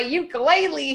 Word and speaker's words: ukulele: The instructor corrected me because I'm like, I got my ukulele here. And she's like, it's pronounced ukulele.